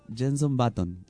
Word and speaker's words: Jenson Button: Jenson Button.